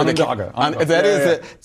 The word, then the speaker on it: Onondaga: Onondaga.